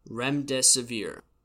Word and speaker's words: remdesivir: Remdesivir. I actually did look that up and make sure I pronounced it correctly, so a little pat on my own back there.